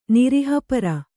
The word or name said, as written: ನಿರಿಹಪರ